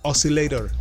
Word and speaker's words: Oszylayter: sino que se escribe, tiene una forma rara de escribirse, es OSZYLAYTER. Oscillator. Bueno, así es la pronunciación, pero se escribe de esa manera.